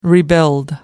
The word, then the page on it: rebuild: American English